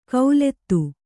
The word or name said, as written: ಕೌಲೆತ್ತು